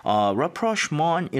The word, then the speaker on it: rapprochement: Rapprochement 이라고 하는 거 이거는 이 발음 자체도 그렇고 스펠링도 그렇고 뭔가 pure English 같지 않은 느낌이 좀 들어가요. – Mais oui, monsieur. It is French.